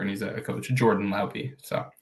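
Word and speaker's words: Laube: It's, I looked it up, by the way. It's Lauby. That's how you say it.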